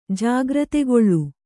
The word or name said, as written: ಜಾಗೃತಗೊಳ್ಳು